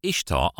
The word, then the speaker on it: Ishtar: Ishtar, I-S-H-T-A-R, is actually pronounced Ishtar, and was also associated with the Roman goddess Venus.